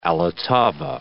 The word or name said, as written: all' ottava